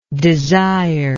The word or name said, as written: desiringly